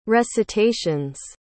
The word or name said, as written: recitations